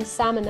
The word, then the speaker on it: salmon: Why is there an L in salmon and why don't we pronounce it? – Well, and you know I'm learning Spanish.